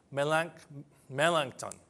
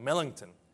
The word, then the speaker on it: Melanchthon: He brought along with him a man named Philip Melanchthon. I'm pronouncing it wrong. Melanchthon.